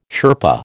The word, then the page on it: Sherpa: American English